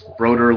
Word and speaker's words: Brodeur: His dad actually put a post on on IHO and said it's Brodeur like motor. – Brodeur like the old Devil's stalwart.